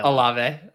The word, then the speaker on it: Olave: Olave.